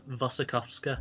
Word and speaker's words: Wasikowska: Yeah, he says it as Wasikowska.